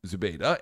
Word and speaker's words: Zubeda: Zubeda. I think I pronounced that correctly. Do you have a better pronunciation? – No, I think that's exactly. You hit all the syllables.